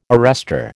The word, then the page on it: arrester: American English